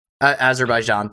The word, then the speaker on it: Azerbaijan: So then we decided to download 2K21 Golf, do a creative player from, I think it's pronounced Azerbaijan. Azerbaijan, thank you.